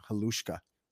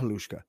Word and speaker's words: Halushka: How do you say her name? Halushka. Olena Halushka.